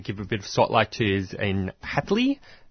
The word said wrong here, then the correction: Haiti: Is that how you pronounce it? – Haiti. – Haiti.